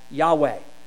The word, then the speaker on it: Yahweh: We often pronounce this as Yahweh.